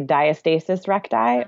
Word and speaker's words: diastasis recti: Am I saying it right? Okay. – Almost. So that is number one on my list. – Okay. – So there's two ways to say it. You can say diastasis recti or diastasis recti.